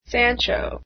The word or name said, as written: sancho